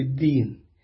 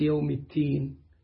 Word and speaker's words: الدين: التين طبعا في ناس بيقول لك اللي لادها نتكلم في التين، يعني هم بس أصدهم حاجة تانية، أصدهم يتكلموا في الدين بس ما بيعرفوش ينطقوا كلمة الدين صح، فيقول لك النهاردة نتكلم في التين. حتى عندنا قراء للأسف من الأتراك لما يقرأ سورة الفاتح والمالك يوم التين أو معيد الصلاة، فالتين احنا عندنا التين على نوعين، برشومي وشوكي، والاثنين برضو حلوين.